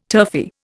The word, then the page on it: turfy: Indian